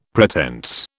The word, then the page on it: pretense: British English